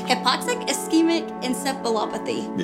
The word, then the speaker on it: hypoxic ischemic encephalopathy: He was diagnosed with HIE, which is his hypoxic ischemic encephalopathy.